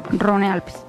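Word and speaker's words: Rhône-Alpes: Eso es Averno Rino, Rino Alpes. – Rone Alpes. – O Rone Alpes, sí. Averno Rone Alpes.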